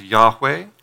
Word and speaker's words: Yahweh: And we are pretty sure that it would have been pronounced Yahweh.